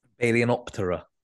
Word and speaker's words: Balaenoptera: Balaenoptera. I'm still not 100% sure, but I think I found out that after our conversation, that the P is pronounced because it's part of an inner syllable.